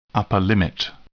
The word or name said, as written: upper limit